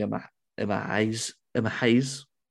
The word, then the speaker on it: Emma Hayes: We're probably going to do an episode on the Emma Hayes, it's pronounced the H, era.